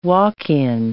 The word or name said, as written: walk-in